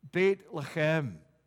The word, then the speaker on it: Beit Lechem: Think of Bethlehem, Beit Lechem. That's literally how it's said in the Hebrew, Beit Lechem.